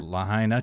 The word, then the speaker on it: Lahaina: Lahaina town, a popular shopping and dining area.